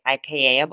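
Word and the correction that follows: açaí: bowls. – Okay. – Acai bowls.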